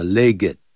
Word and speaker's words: legged: Before the age of mechanization, 在机械化的时代到来之前，就等于 before the coming of the age of mechanization, camels, horses, donkeys, and other four-legged creatures, 像骆驼啦、马啦、 donkeys, 驴子啦、 and other four-legged, 这个 legged, 啊，一定要那个 ed 啊发音的 legged,